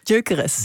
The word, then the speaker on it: Gyökeres: Ja, ik zit dan met die naam, hoe spreek je het uit? Hoe zeg je het? – Gyökeres. – Gyökeres, ja.